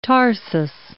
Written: tarsus

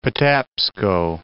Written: Patapsco

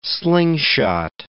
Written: slingshot

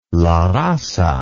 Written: La Raza

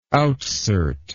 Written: outsert

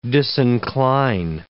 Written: disincline

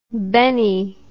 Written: bennie

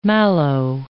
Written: mallow

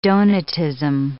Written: Donatism

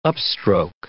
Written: upstroke

